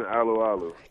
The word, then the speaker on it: Alualu: Tyson Alualu, or how do you say it? – Tyson Alualu. – Yeah, people keep telling me it's Alualu, and it's a little less fun for me to say.